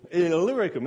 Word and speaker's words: Illyricum: Illyricum, that's how you say it.